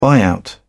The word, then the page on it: buyout: British English